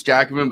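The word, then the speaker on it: Jacobin: For the record, it's Jack, like the name Jack O, and then Bin, like trash bin. – I didn't believe that like he couldn't pronounce Jackman,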